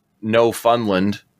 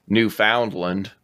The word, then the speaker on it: Newfoundland: How it hit Newfoundland or Newfoundland. Everybody outside of Canada mispronounces Newfoundland.